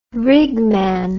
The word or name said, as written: rigman